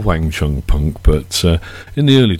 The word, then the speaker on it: Wang Chung: We are called Wang Chung. That's how you say it.